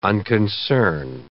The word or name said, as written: unconcern